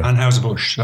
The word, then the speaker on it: Anheuser-Busch: So every year, Ann Huser Bush Anheuser-Busch. – Yeah. – Anheuser-Busch.